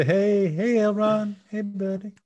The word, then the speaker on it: Elrond: You see Eldron. – Okay. Elrond? – Did I say it wrong? Eldron. No, still not. There's no D at the beginning.